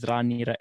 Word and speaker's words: Raniera: I'll say my name's Raniera and I'll just spell it out straight away.